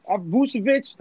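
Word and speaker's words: Vučević: Vucevic, how you say his name? Vucevic. – You said it right the first time.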